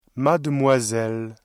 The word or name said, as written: mademoiselle